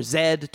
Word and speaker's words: Z: So that's The Lost City of Z, or Zed, depending on your pronunciation.